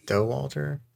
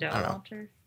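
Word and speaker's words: Dauwalter: Is that how you pronounce it? – I think it's Doe Walter, something like that. – Doe Walter.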